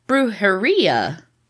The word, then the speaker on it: brujería: My uncle's grandparents, who started the business, are said to have practiced brujería." – Uh-huh. – Spanish for witchcraft. – Yeah. – But I don't think I said that right. – Yeah, I think you did.